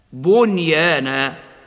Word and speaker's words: بنيان: فالنون الساكنة قبل الياء من كلمة وقعت في لفظي الدنيا بنيان، ووقعت قبل الواو في كلمتي صنوان وقنوان، كما ننطق بالنون الساكنة مظهرة قبل الياء هكذا: وما هذه الحياة الدنيا إلا لهو ولعب، الدنيا. وأيضا: فقالوا ابنوا عليهم بنيانا، بنيانا.